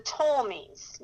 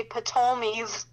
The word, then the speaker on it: Ptolemies: And so the king of the south was the Ptolemies. Now, if you look at it, the spelling of it, it's it starts PT, so you'd almost want to say Ptolemies. it's silent P, so we're just going to say Ptolemies.